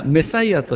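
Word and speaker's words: Messiah: で、ザ・メシアとひらがなで書いてありましたけども、メシア、あるいはメサイアというふうに英語では発音しますけどもね、救世主。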